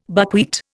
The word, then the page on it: buckwheat: Indian